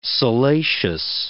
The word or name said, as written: salacious